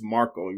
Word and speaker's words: Markle: And I did say his name incorrectly. It's Markle. You're right. You said it right, Jack. I said it wrong.